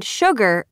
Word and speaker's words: sugar: Sure and sugar are the only two words in the English language that begin with S-U and are pronounced sh.